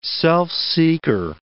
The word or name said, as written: self-seeker